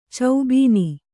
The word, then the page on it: ಚೌಬೀನಿ: caubīni